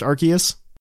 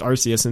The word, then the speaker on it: Arceus: Like, I think in the Arceus movie, it's pronounced Arceus. – Correct. And it's with the hard K.